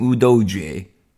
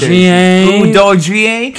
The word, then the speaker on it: Udogie: Now, in the 26th minute, Udoji, the commentator calls him Udoji. And by Jerami, we really need to work on our pronunciation. – Nah, maybe they need to work on their pronunciation.